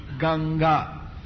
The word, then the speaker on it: Ganga: In Sanskrit, Ganges is pronounced with the two-syllable word Ganga.